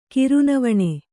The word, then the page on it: ಕಿರುನವಣೆ: Kannada